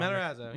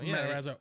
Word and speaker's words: Matarazzo: Nick Matarazzo. – Oh. – I'm sorry if I said the name wrong. – Matarazzo. – Matarazzo. – Yeah.